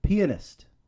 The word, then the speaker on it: pianist: I got to really pronounce that word, pianist. That'd be weird if I just called him a great drummer and quite a penis.